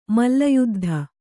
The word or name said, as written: ಮಲ್ಲಯುದ್ಧ